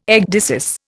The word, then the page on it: ecdysis: Indian